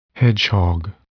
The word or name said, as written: hedgehog